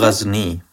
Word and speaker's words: Ghazni: In Ghazal? Is that how I pronounce it? – Ghazni. – Sorry, Ghazni. – That's right, yeah.